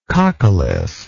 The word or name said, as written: coccolithic